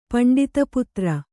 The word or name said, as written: ಪಂಡಿತಪುತ್ರ